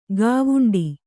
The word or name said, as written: ಗವುಂಡಿ